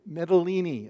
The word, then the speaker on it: Mytilene: When he met us at Assos, we took him aboard, and we went on to this next city, Mytilene. It sounds Italian, but that's how it's pronounced.